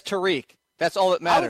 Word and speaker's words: Tarik: Saying the reason I would love it is because Tarik is the natural way I would pronounce it. So I can, instead of having to think about it all the time, I can just go with my natural pronunciation.